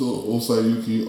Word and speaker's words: Osayuki: Just search up my name, Osayuki. You'll find me on all four platforms. – Spell it for them, bro. – So Osayuki, OSAYUKI.